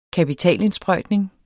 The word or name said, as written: kapitalindsprøjtning